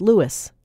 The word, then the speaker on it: Louis: For starters, his name, in fact, was Louis. Terry Teachout says Louis would be the pronunciation for lighter-skinned black Creoles descended from the French, a group with higher social status in early 20th century New Orleans.